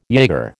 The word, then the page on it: jaeger: American English